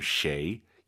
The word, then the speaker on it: 谁: 쉐이, 쉐이 해도 알아듣지만 정확한 발음 쉐이, 입을 가로로 쫙 찢으면서 쉐이 발음하시기 바랍니다.